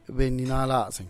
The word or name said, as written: bennynalazen